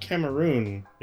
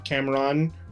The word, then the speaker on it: Cameroon: So Yefit Kyoto, his father was, I believe it's pronounced Cameroon. Is that correct? Or yeah, that's right. – Cameron, but it's Cameroon.